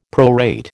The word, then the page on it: prorate: American English